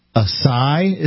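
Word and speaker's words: açaí: It's chewable, and it includes fruits and berries, including goji fruit, mangosteen, acai is how it's pronounced, okay.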